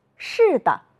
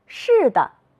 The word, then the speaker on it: 是的: 扇子，是的，耷拉，竖着。你发现了什么？有的同学说，老师，后一个字都要读成轻声。你们真会观察，我们再来读一读。扇子，是的，耷拉，竖着。